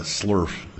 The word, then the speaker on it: SLURF: The SLURF is how we pronounce that acronym, even though it doesn't quite look like that.